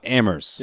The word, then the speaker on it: Amherst: We know it's pronounced Amherst. – Yeah. – No, you don't put the H in there.